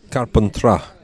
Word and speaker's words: Carpentras: Carpentras. – I mean, it's got an S at the end. You don't pronounce the S.